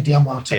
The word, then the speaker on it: D'Amato: Is it Anthony D'Amato? – Anthony D'Amato. – D'Amato. – Pronounces it.